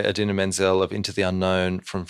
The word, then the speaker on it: Idina Menzel: The nice Idina Menzel gag. – It's pronounced exactly how it is spelt.